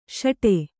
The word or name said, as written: ಸಟ್ಟಾ